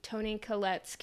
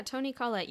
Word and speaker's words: Toni Collette: The father is a psychologist, and Toni Collette's character. Am I saying her name right? – Yeah, Toni Collette. You did wonderfully.